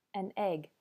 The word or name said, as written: an egg